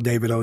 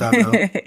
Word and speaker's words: Davido: Some people call it Davido. It's pronounced Davido.